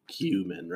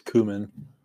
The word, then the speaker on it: cumin: I think the U.S. cumin. – Oh, right. – Yeah, so it's only in the U.S. Everywhere else, it's cumin. – Well, they're wrong, because it's native to southwestern Asia, including the Middle East. – Yeah, no, they're wrong itself. It is an American mispronunciation.